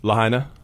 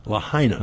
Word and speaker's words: Lahaina: Lahaina. – Lahaina.